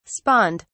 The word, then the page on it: spawned: British English